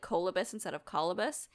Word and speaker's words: colobus: I actually looked up the pronunciation for four different sites tonight before I recorded this episode, and they all said colobus.